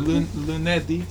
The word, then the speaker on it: lunedì: Has an accent on the end. – Yes. – Ah, lunedì. – Okay. – All of them have an accent on the end. All of them finish with D.